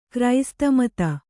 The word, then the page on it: ಕ್ರೈಸ್ತಮತ: Kannada